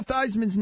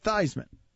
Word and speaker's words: Theismann: Joe Theismann's name is not Theismann. – I didn't know that. – He changed his name. It's Theismann. – Mm-hmm. – He changed his name to rhyme with Heisman.